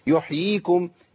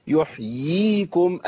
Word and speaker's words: يحييكم: آه كتير من الناس يقول إيه؟ ثم يحييكم. يا أخي الله يكرمك، خد بالك أنت أسقطت ياء كاملة. يحييكم يحييكم، أيوة كده.